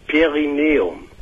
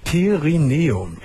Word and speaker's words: Perineum: Ja, wie heißt das nochmal ausgesprochen, genau? – Also Perineum. – Perineum. – Perineum. – Perineum. – Perineum, das ist wohl lateinisch.